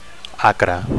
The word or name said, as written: acre